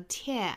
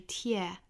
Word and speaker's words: tear: 后面这个 tear 注意发法，元音不要发成了 tear 哈，要注意是 tear ear ear falls like tears。